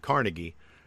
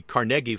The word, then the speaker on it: Carnegie: Carnegie there. It's not Carnegie. In Pittsburgh, the Carnegie family pronounces it Carnegie, and that's how it's pronounced in Pittsburgh.